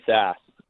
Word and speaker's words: Sass: People usually just call me Sass. – All right. – One syllable, the rare one. – One syllable, yeah, exactly, or Sasser sometimes.